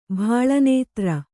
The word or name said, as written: ಭಾಳನೇತ್ರ